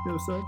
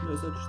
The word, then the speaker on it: Nosaj: Nozaj? How do you say that? – Nozaj? – Yeah, oh. – Like Nozaj thing.